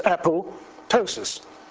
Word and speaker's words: apoptosis: But most people say there's apoptosis, and it says, no, it isn't, it's apoptosis. Is a silent P.